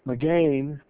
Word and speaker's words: magen: That word shield literally comes from a Hebrew word, that magen, magain, let me say it right, magain, that means covering, buckler, and shield.